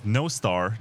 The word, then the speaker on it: Nostr: co-founders of Nostr. That's the way you pronounce it.